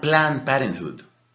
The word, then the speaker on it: Planned Parenthood: Se llama Planned Parenthood. Se escribe algo así como Planet Parenthood. H-O-O-D termina.